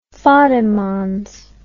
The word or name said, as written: fattigmanns